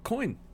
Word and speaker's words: Coin: I guess I wouldn't have assumed that it was S-N-O, President Snow. President S-N-O with an umlaut. President Snow. John Snow. but yes, coin. Spelled like the object. Spelled like the currency.